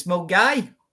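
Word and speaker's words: Milngavie: Do you know how to pronounce this town? – Mungavi. – That's what I said. – It's Mungai.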